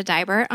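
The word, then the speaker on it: Deibert: You can follow me at Amanda Diebert on Twitter, and my last name is German, so it's D-E-I-B-E-R-T, even though it's spelled like die, or said like die.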